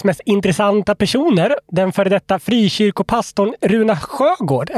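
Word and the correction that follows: Søgaard: är det så det uttalas? – Sörgård.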